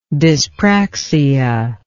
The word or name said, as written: dyspraxia